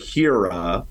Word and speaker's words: HEERA: Although there's a catch, but the rebate programs, I'll just say the initialism, it's like the word homes, H-O-M-E-S. And then there's a separate rebate program. You say it HERA, but it's spelled H-E-E-R-A. – Okay, now you have to say it again. Would you be so good? H-O-M-E-S.